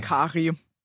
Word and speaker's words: Kary: K-A-R-Y, wie würdest du den Namen aussprechen? Carrie oder Carrie? – Carrie.